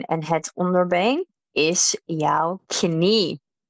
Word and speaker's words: knie: So contrary to English, we do pronounce the K when it is in front of an N. So it's pretty much written the same, except we do pronounce the K. Knie. Knie. Onder jouw onderbeen heb jij een voet.